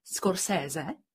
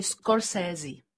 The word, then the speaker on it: Scorsese: že jeho jméno má dokonce dvě výslovnosti. Jedna je italská, Scorsese, a pak američani říkají Scorsese. Takže my budeme říkat Scorsese. A to je takový oslý mustek k tomu, aby si nám řekla, proč vlastně má takové divné jméno No, eh, to jsem zrovna chtěla navázat, přesně tak. Oni mu to jméno vlastně zkomolili. Nicméně je to tak, jak si přesně říkala, on vlastně se vyslovoval bez toho R, vyslovoval se z Koseze, on byl z Itálie.